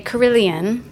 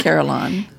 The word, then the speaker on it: carillon: The British would say carillon, and the Americans would say carillon. – It's a carillon.